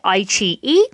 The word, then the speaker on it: iQIYI: There may be others, but the ones that I know of And that is, the first one is Aiqi, and that is spelled I-Q-I-Y-I, but it said Aiqi.